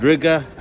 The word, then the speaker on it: Riga: I'm just walking around Riga at night, which rhymes with nigga.